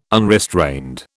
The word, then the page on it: unrestrained: British English